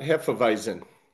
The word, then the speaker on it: Hefeweizen: This is apricot Hefeweizen.